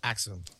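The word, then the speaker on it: Axiom: They don't do the A. Yeah, right, they don't so pronounce Axum. The A. It's Axum.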